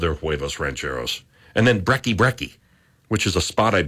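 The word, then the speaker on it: Brekki: And it's spelled B-R-E-K-K-I. B-R-E-K-K-I. Brecky.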